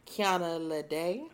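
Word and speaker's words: Kiana Lédé: Now, correct me if I'm pronouncing this wrong, but it's just Kiana Lede. – That sounds about right to me.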